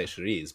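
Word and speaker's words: chouriço: Well, I mean, if I was being Portuguese, I'd say Chiriz, but I'm...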